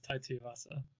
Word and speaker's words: Tuivasa: How do you say his name? – You're close enough. Tai Tuivasa.